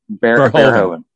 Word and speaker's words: Verhoeven: Verhoeven.